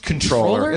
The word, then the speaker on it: comptroller: Did you know it's pronounced controller? – It's pronounced controller.